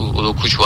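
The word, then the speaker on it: Ugochukwu: Udokuchu diye okunuyor galiba. Udokuchu, aynen.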